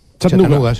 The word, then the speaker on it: Chattanooga: ¿Se pronuncia así en Chattanooga? – Chattanooga. Sí. Chattanooga City.